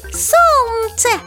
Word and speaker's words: солнце: Согласная Л. – Солнце.